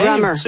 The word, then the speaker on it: Grammar: En l'écoute de Bonne Nuit Chérie et Soleil Secret, j'ai pensé à un groupe qui s'appelle London Grammar. Je prononce bien? – Grammar. – Grammar, ouais.